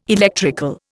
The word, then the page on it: electrical: Indian